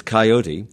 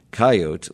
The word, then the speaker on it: coyote: So the preferred pronunciation is coyote, with the Americanized version coyote listed in most dictionaries as a secondary pronunciation.